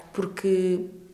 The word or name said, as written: porque